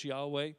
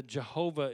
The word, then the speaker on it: Yahweh: We just know that probably the closest English pronunciation of it is Yahweh. The Jehovah is the more accepted pronunciation, but Hebrew does not have a J sound. There is no J sound in Hebrew, so it could not be Jehovah.